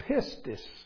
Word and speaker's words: pistis: Now the word, the Greek word that is translated faith is the word pistis. P-I-S-T-I-S in English. I won't try to, but that's how we would pronounce it. Maybe that might not be exactly correct, but it's fairly close.